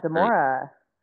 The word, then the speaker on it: Demora: One model I wanted to ask you about for I know I'm probably gonna say her name wrong, but it's like Demra something, where she has like really big. – Yeah, Demora.